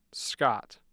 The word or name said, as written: Scott